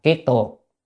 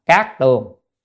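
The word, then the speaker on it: kiết tường: Biến là khắp, kiết là kiết tuồng. Ở thế gian người ta hay đọc là cát tuồng, nhưng trong Phật Pháp đọc là kiết tuồng nha.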